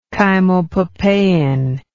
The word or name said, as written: chymopapain